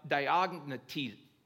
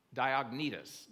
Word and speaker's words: Diognetus: And so it's called the Epistle of, I'll say it right, the Epistle of Diognetus. Diognetus. There we go. I knew I'd get it out. Diognetus. That's what it's called.